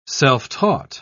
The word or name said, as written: self-taught